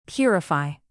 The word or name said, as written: purify